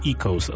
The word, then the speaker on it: echoes: Chciałem o to powiedzieć, że większość moich słuchaczy w grupach by powiedziało Echoes, co jest nieprawdą, dlatego że to słowo się tak nie wymawia. Wymawia się tak, jak po polsku trochę, czyli Echoes, a nie Echoes.